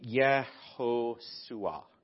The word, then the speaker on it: Yehoshua: The pronunciation would be Yehoshua, Yehoshua.